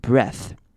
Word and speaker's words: breath: 这边 breath 它的 ea 会念成短母音 e 的声音， breath breath bad breath， but you'll be super chill。